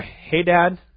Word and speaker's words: Hadad: But they were Hadad, the Edomite, that is the right pronunciation, by the way, and Rezon.